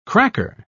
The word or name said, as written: cracker